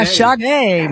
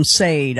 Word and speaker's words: Sade: Yeah, Sade, man. Yeah, exactly. Yeah, I said it wrong on live on radio. I called him Sade. I'm like, oh, I never lived that one down. So, yeah, Sade.